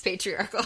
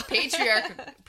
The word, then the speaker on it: patriarchal: patriarchal. Patriarchal. Patriarchal, not patriarchal. I'm putting an extra syllable in there.